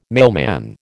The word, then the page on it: mailman: American English